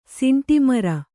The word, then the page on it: ಸಿಂಟೆಮರ: siṇṭe mara